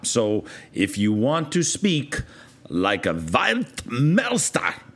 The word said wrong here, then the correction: Weltmeister: no, I said that wrong, Weltmeister, perhaps, German friends, that means a world master, all right, or master of the world of soccer.